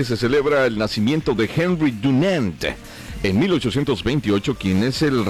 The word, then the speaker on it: Dunant: Ok, Donant.